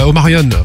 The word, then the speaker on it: Omarion: Omarion, voilà, pour bien le prononcer.